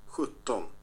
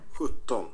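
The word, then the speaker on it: sjutton: Hütton. 17. So spricht man 17 aus. Ich muss sagen, die Zahlen sind hier im Schwedischen schwieriger auszusprechen als im Norwegischen. Aber kann man ja alles lernen. Hütton.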